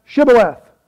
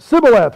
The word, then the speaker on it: Shibboleth: Verse 6, they said unto him, say now, Shibboleth. And he said, Sibboleth, for he could not frame to pronounce it right.